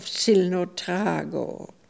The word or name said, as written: Zilnotrago